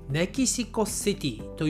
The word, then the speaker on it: メキシコシティ: えー、少しね、カタカナっぽい言い方にはなります。メキシコシティ。ね、シティと言わないですね。メキシコシティと日本人は発音します。